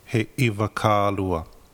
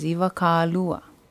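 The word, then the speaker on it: iwakālua: Beginning Hawaiian language students sometimes mispronounce the number Ivakaalua by inserting an okina like this. – He Ivakaalua. – This is not pololei. The correct pronunciation is Ivakaalua or He Ivakaalua.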